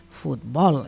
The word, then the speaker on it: futbol: I fins i tot hi ha errades a cartells que, a veure, en català és futbol. És una paraula que diem aguda, és a dir, que la força a l'hora de pronunciar-la recau a l'última síl·laba, l'última...